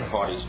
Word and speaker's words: haughty: And the reason they put an haughty spirit is because the H has a tendency to be silent, and lots of the times people wouldn't even pronounce the H. They'd say an haughty spirit instead of an haughty spirit.